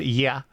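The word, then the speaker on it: ya (я): Well, because it's the Russian letter that looks like an English E. It's pronounced ya.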